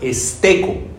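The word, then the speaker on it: esteco (στήκω): Bueno, esta palabra firmes, sí me gustaría que la miráramos en su raíz, que es en el griego, viene de la palabra esteco, tilde la E. Bueno, ahí para la pronunciación, ¿no? Esteco.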